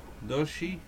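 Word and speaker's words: Dodge: Doge Challenger.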